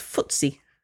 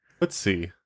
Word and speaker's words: FTSE: pronounced FTSE. FTSE.